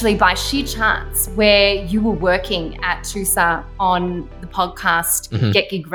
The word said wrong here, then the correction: 2SER: It's 2SER, pronounced one letter at a time.